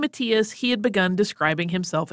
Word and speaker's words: Matthias: This religious network eventually led Isabella to a man named Robert Matthews, who had taken the name Father Matthias, which is spelled Matthias. Some people might pronounce it that way. Most Germanic languages say Matthias, so we're going with that.